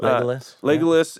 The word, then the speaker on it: Legolas: Yeah, Theoden, and well, you have Legolas. Is that how you say his name? – Legolas.